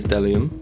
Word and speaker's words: bdellium: That's a rich history of the word bdellium. Wow. – Drop a knowledge problem. – Wow. – But I think it is actually pronounced without the B. I think it is delium.